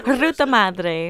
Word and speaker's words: Ruta Madre: Star of Ruta Madre. I'm trying to say it like you said it off the cuff. – Ruta Madre.